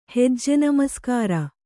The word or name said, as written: ಹೆಜ್ಜೆನಮಸ್ಕಾರ